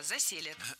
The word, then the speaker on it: заселят: Конечно, не заселят, а заселят. Здесь ударение ставим на корень слова – заселят.